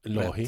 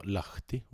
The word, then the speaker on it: Lahi: Det ja, men det kan ju bero på att jag sa Lahti. Och det finns inget T ens med i hans namn.